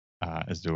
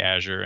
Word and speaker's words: Azure: Yeah, see, what I would always use the Z sound. The question is whether we would accent the first syllable or the second one, Azure or Azure. So, well, you say Azure and you work at Microsoft, so we're rolling with that.